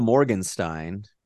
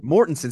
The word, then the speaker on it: Mortensen: Mortensen.